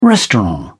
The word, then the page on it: restaurant: British English